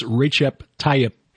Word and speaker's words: Recep Tayyip: I'm reliably informed it's Recep Tayyip. A fellow who was an expat teaching English in Turkey for several years, Recep, the first name.